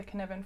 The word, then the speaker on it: Cynefin: Last week we talked about the Cynefin framework, which isn't spelt how it sounds, but that is how you say it, the Cynefin framework.